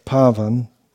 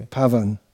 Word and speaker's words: Pavan: Pavan or Pavan, you're pronouncing it Pavan, which is also acceptable, but this Pavan. For this meaning it has to be Pavan.